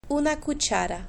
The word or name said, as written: una cuchara